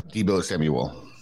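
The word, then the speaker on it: Deebo Samuel: Debo Samuel.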